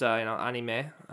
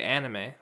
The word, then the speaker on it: anime: Like, in Japanese, it's pronounced, anime, right? But I think most Americans say anime with the ah instead of the ah, you know?